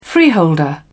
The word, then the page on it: freeholder: British English